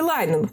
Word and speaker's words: Линехан: Основатель диалектико-поведенческой терапии Марша Линнихан или все-таки Лайнен? Как правильно говорить? Линнихан. Линихан.